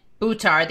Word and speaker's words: Buttar: Shahid, so ah, the accent's on the ah, and Buttar, the accent's on the ooh.